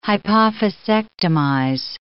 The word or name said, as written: hypophysectomize